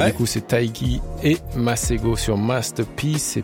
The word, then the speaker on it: Tayc: L'homme s'appelle Taiki. Si jamais vous ne saviez pas, maintenant vous savez. T-A-Y-C. Ça se prononce Taiki.